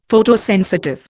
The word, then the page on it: Photosensitive: Indian